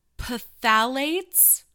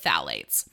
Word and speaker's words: phthalates: Something else to avoid as a, you know, a libido killer, shall we say, are, oh gosh, and I know that I'm going to pronounce this wrong, phthalates. There's a silent P, I think. We'll go with phthalates.